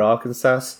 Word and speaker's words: Arkansas: The S on the end is silent. So it looks like Arkansas, but it's Arkansas.